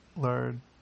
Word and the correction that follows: Laird: I don't think his name is Lard. It's L-A-I-R-D. Laird Hamilton.